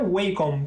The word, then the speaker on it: Wacom: Então, eu acho que a pronúncia correta é Wacom, que é W-A-C-O-M, né?